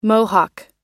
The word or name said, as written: mohawk